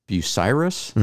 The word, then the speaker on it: Bucyrus: Bucyrus. Am I saying that right? – Yeah. – And as far as I know, Bucyrus,